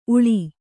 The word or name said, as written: ಉಳಿ